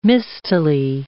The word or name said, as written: mistily